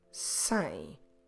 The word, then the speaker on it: cem: Don't mix it up with sem, meaning without, starting with the letter S. So this one is the sem with a C. Como se escreve sem? C-E-M. Sem. Cedo. Early. Como se escreve cedo? C-E-D-O. Cedo. Cebola. Onion. Como se escreve cebola? C-E-B-O-L-A.